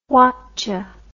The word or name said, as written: whatdya